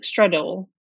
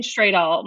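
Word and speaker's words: Stradal: He says it's Stradul, like Stradul. Stradul. – Oh, okay. – So okay. I've been saying straight all my whole life. It's Stradul.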